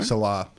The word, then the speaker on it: Salah: Salah. Is that how you pronounce it? – Yeah. I don't know.